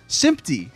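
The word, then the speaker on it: SMPTe: Sympty, is that how you pronounce it? – That's apparently, I learned that this week. – I've been pronouncing it sept, September. – It's spelled S-M-P-T colon little E, right?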